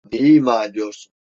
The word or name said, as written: ima